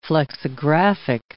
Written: flexographic

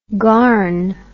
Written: garn

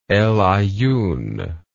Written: El Aaiun

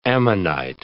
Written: ammonite

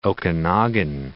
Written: Okanagan

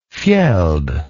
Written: fjeld

